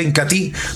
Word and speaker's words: Incatea: Incatí, En presentación de medio litro se escribe Incatea, pero se pronuncia Incatí.